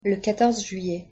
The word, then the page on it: le quatorze juillet: French